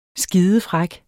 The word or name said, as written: skidefræk